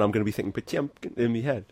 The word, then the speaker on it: Potemkin: So something that makes me think, you know, of maybe literary or pop culture references would be your song Potemkin, or as my former Russian theatre professor would call it, Putiemkin. you're saying it wrong.